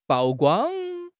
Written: 曝光

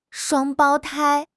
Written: 双胞胎

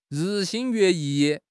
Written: 日新月异

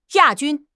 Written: 亚军

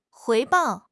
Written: 回报

